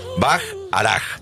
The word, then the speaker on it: Bacharach: Ustedes donde vean la firma de Burt Bacharach, Burt se escribe, y como si fuera Bach, como Johann Sebastian Bach, Bacharach.